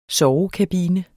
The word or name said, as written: sovekabine